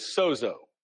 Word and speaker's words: sozo: And the term made well, the Greek word is sozo, S-O-Z-O.